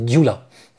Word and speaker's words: Gyula: Ja, fast. Also das Ungarische hat ja mehr Buchstaben als wir im Alphabet. Das G und das Y ist dort ein Buchstabe, das ist Gyula. Also Gyula. – Gyula, ja.